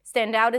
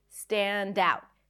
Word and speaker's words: stand out: Stand out is another phrasal verb. And in phrasal verbs, we stress the second part. Stand out. Stand out.